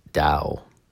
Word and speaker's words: Dao: Do is the Japanese way of pronouncing the Chinese Dao.